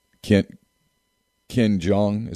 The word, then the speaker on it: Ken Jeong: Actor from The Hangover, the comedian, Ken Jeong.